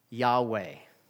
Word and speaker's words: Yahweh: In Hebrew, that is pronounced Yahweh.